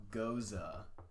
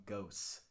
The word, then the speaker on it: Gose: Goza. Yeah, like sour beer. I always call them ghosts, like G-O-S-E. But apparently they're called goza. Do you know what I'm talking about? – G-O-S-E? – Yeah.